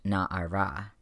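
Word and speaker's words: Na'ara (נערה): And when those Masoretes came across Rebecca getting called Na'ar, they added a vowel marker to the word so that it would be pronounced Na'ara, young woman, instead of young man.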